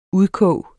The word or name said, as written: udkog